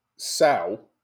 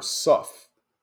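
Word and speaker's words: sough: Sow or suff, both are accepted pronunciations.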